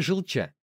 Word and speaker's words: Желча: что на реке Желча, произносится с ударением на последний слог, чтобы спустить новое судно на воду.